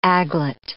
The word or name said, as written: aglet